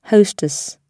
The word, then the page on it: hostess: British English